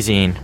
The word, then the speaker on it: zine: Probably zine. Rhymes with magazine.